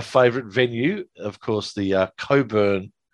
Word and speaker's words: Cockburn: But I think the way you spelled that, you want me to say Cockburn. – That is how it's spelt. – What, C-O-C-K? – Yes. – Are you talking about the guy that was on the electorate still? – That's how you spell Coburn.